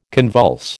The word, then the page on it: convulse: American English